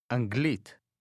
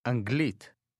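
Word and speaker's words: Anglit (אנגלית): Anglit. Glit. Glit. Anglit. Anglit. – How do you say English? – Anglit.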